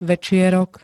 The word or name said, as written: večierok